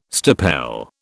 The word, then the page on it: stipel: British English